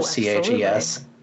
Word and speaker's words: CHES: We've universally acknowledged that it's pronounced as a word instead of CHES.